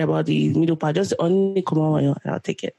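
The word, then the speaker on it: Onni (언니): So since it's female to female, you can call me Onni. And it's spelled E-O-N-N-I, Onni. That's the romanization. Or you can do U-N-N-I. And then Komawoyo is G-O-M-A-W-O-Y-O, Komawoyo.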